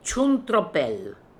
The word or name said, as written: Choon Tro Pel